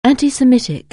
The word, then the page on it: anti-Semitic: anglais